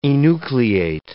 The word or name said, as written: enucleate